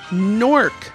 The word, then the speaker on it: Newark: We heard some music from Newark, New Jersey. See, sometimes I drop a syllable. It's a Middle Atlantic thing to a lead consonants. It's a New Jersey thing to pronounce Newark as Nork, N-O-R-K.